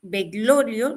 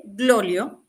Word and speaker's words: Bergoglio: El Papa Francisco, para recopilar un poquito de información, es un papa de origen argentino que se llama Jorge Bergoglio, no lo estoy pronunciando bien, Bergoglio,